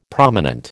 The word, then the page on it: prominent: American English